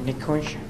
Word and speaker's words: Nikunj: Nikunj Patel. – Can you spell? – N-I-K-U-N-J Patel, P-A-T-E-L.